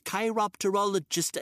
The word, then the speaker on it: chiropterologist: Uh, chiropterologist.